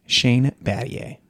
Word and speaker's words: Battier: Shane Battier. – Good job pronouncing his name right.